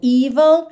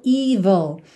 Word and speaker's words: evil: Atenção, muita atenção à pronúncia dessa palavra, porque ela é escrita E-V-I-L. Então, se a gente bater o olho e ler como se diz em português, vai ser evil, que é praticamente o inverso da pronúncia correta, certo?